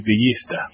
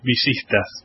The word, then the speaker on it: Villistas: Yeah, because in Argentina we say Villistas, but it's something different. Not different. – The accentation might be slightly different. – Yeah, we pronounce the double L like a sh. So it's Villistas.